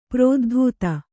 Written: ಪ್ರೋದ್ಧೂತ

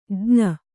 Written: ಜ್ಞ

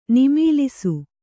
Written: ನಿಮೀಲಿಸು

